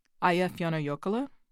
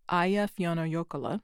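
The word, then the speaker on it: Eyjafjallajökull: Aya Fiona Yokola. Aya Fiona Yokola. Aya Fiona Yokola.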